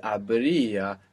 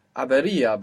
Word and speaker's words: habría: Now, I'm not sure if you said habría or habería, because this irregularity that we have, which is habría, happens so naturally because that E kind of falls out. So naturally, I think you said habería, but you kind of didn't pronounce the E well.